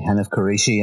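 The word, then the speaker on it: Hanif Kureishi: I just read a book called The Buddha of Suburbia by Hanif Qureshi, and I probably just butchered his name.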